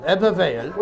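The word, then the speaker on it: Ebbw Vale: Ebervale, right, which is spelled E-B-B-W, Vale, Ebervale.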